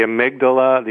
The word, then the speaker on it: amygdala: that the amygdala, the activity actually calmed down.